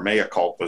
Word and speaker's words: mea culpa: Well, I got to give a mea culpa again, or mea culpa is a better pronunciation, because I've been largely AWOL from that.